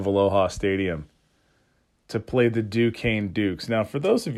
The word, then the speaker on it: Duquesne: It's Duquesne. That's how that's pronounced. It's very French.